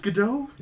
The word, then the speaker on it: Gadot: Because Gal Gadot... Gal Gadot? – Gadot. – Gadot? – Yeah. – It's Gadot? – Yeah. Gal Gadot. – What? Why not? It's not French. – No, it's Israeli.